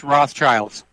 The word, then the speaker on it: Rothschild: So, by the way, it's pronounced Rothschilds.